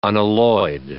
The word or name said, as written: unalloyed